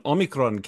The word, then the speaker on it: Omicron: Well, as a newscaster, I had some difficulty reporting on the COVID crisis because when Omicron came out, I was pronouncing it in the Greek way and rolling my R's.